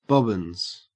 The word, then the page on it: bobbins: Australian English